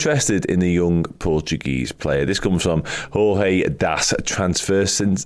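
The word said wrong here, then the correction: Transferências: I can't pronounce that word. Transferencias. There we go.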